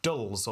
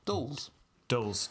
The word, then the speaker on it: Dulles: Still, Dulles or Dulles? You know what? This is another case of me writing a name and not checking the pronunciation and then forgetting to do it. D-U-L-L-E-S. – Dulles. – Dulles. Dulles. He was French.